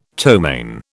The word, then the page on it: ptomaine: British English